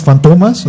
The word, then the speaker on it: Fantomas: Está basado en el personaje francés Fantomas, así se pronuncia.